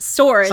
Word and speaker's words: sword: I don't know how to say the word sword. Double-edged swords. – Sometimes you forget that W is in there.